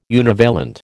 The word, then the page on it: univalent: American English